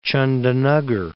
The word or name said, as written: Chandannagar